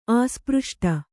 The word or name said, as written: ಆಸ್ಪೃಷ್ಟ